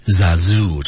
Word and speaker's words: Zazu: Oh now look Zazu, Oh now look Zazu, 注意 Zazu 这个关键词的读音 Zazu 特别容易出错，千万别读成 Zazu，那就很难听了啊。